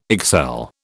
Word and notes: excel: British English

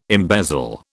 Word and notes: embezzle: British English

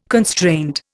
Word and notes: constraint: Indian

